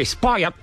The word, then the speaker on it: spoiled: Esse, aqui, spoiled. Você engole o finalzinho.